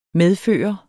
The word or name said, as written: medføre